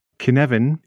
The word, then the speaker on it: Cynefin: Kinevin is a Welsh word for habitat and is spelt C-Y-N-E-F-I-N.